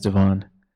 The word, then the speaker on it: Devon: Hi, my name is Devon. Devon? – Devon? – Yeah. – Spell it for me. – It's D-E capital V-O-N.